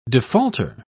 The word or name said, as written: defaulter